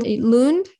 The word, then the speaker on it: Lund: So you're in Lund? Am I saying that correctly? – That's right.